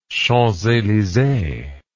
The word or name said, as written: champs elysees